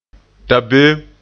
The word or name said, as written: dabeem